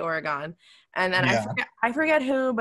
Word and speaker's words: Oregon: He's like, it's Oregon. Like, don't ever say Oregon. And I was like, crawls under our skin when we hear it. – Right. And everybody I talk to here, they pronounce it Oregon. And I have to correct them. I'm like, it's Oregon.